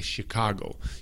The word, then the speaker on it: Chicago: Se pronuncia de la misma manera que Chicago. Chicago. Chicago. Es con C-H. C-H. Pero suena como si fuera SH, ¿me entienden? Charlotte, Chicago, ¿ok? Pero Charlotte se deletrea C-H-A-R-L-O-T-T-E. C-H-A-R-L-O-T-T-E.